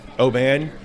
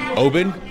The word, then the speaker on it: Oban: It's not Oban, it's open, like, hey, let's open another bottle so we can keep going? – It's interesting.